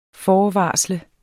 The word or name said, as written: FORVARSLET